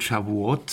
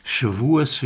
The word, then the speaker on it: Shavuot: Ende März war Pesach und Mitte Mai wird Shavuot sein oder Shavuos, wie man in der aschkenasischen Aussprache sagt.